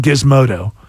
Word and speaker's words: Gizmodo: This is from Gizmodo. That's with the hard G. – Uh-huh. – Because the one with the J, that's a totally different...